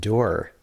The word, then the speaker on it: dour: And she's like, no, it's not pronounced dour, it's pronounced dour.